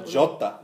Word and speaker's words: Jota: Is it Jarta? Genta? – Jota. – Jota.